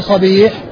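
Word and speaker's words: صَبِيح: فالنعمان بن صبيح والده مفتوح بفتح الصاد وكسر الباء، وأما أبو الضحى مسلم بن صبيح فأبوه بضم الصاد وفتح الباء.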